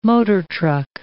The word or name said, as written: motortruck